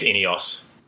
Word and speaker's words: INEOS: In doing so under, I don't know, do you pronounce it Ineos or I-N-E-O-S? – I think it's Ineos. – Ineos, okay. So Ineos has bought into the team.